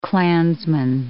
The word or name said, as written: Klansman